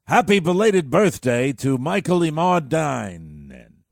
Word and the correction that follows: Imordino: That's not how you say it. Try it again. – Happy belated birthday to Michael Immortal. – No. – Immortal. – Happy belated birthday to Michael Imordino. – Better.